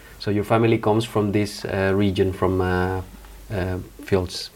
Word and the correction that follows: Friedrichshain: Friedrichshain. – Ah, sorry, my pronunciation is not the best.